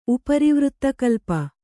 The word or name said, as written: ಉಪರಿವೃತ್ತಕಲ್ಪ